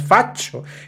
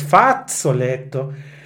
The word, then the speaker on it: fazzoletto: And I have been mispronouncing it, sorry. It is not faccio, it is fazzoletto.